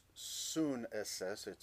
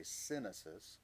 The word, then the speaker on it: sunesis: See, the word is sunesis. It's spelled S-Y-N, so we might say synesis.